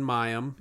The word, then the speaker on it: Mayim: So you're gonna Clinton Mayim? – Yeah.